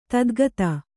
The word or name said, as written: ಧಾತುಗತ